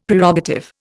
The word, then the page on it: Prerogative: Indian